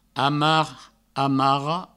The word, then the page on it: amar, amara: amar, amaro